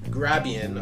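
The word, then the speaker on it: Grabien: So it is pronounced Grabian, apparently, Grabian Transcripts.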